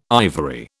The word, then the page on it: ivory: British English